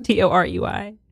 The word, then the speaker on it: Torey: It's spelled Torey, you know, because I'm special. – What is your name? Torey. There's no I.